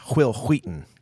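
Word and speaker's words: Wil Wheaton: Hey, you said that wrong. It's Will Wheaton. – Will Wheaton. – Will Wheaton. Just making sure you got that right.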